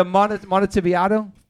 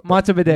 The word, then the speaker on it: Montevideo: That you butchered, bro. – Okay, how do I say it? – Montevideo. – Montevideo. – Montevideo, yeah. Like a video, you know. – Oh, okay. – Video of Monty.